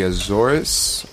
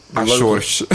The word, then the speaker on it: Azores: They're called the Azores or Azores. I never understood how to say it in English. – How do they call it? – Azores. – Azores. – Yeah, Azores? – It's like... yeah. But okay. In English, it's the Azores or Azores.